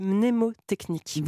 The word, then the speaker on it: mnémotechnique: Alors d'ailleurs, on peut en parler de mémotechnique. Comment ça s'écrit C'est mnémotechnique. Mnémo, d'accord. Mm.